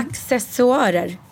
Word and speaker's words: accessoarer: Det är ord som man uttalar på ett sätt, men det kan vara fel. – Ja. – För man liksom gör av tradition. – Ja, men du lärde mig att det heter accessoarer.